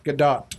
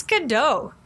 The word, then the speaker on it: Gadot: Gadot. – Okay. You've got to talk about you guys' pronunciations about people's names. – Gal Gadot, that's how you pronounce her name.